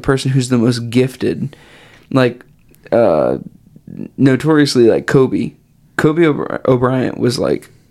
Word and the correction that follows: Kobe Bryant: Kobe Bryant. Kobe, and I said O'Bryant. Kobe Bryant.